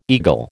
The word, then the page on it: eagle: American English